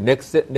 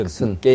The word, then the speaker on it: 넥슨: 그 다음, 뭐, 이건 다 알지만, 뭐, 그, 저기, 넥센, 넥슨입니까? – 예, 넥센. 거기서, 예. 넥, 넥센인가요? 예. 넥센, 뭐 넥센은 넥슨. 타이어 회사 같은데. – 넥슨입니다. – 넥슨. 넥슨.